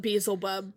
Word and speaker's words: Beelzebub: It is pronounced Beelzebub, but it's Beelzebub. – No, I know it's pronounced Beelzebub.